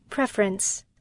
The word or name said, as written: preference